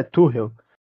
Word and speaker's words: Tuchel: O Tuchel, o tu, na verdade, eu aprendi, eu aprendi que a pronúncia é Tuchel.